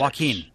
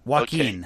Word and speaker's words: Joaquin: Joaquin. – Joaquin. Okay. Joaquin. – Yeah. – River's it's almost brother. Like, well, I know it's River's brother. Come on. Yeah, so Joaquin Phoenix.